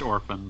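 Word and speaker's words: Orphon: It's clearly supposed to be pronounced orphan, but yeah, it's O R P H O N.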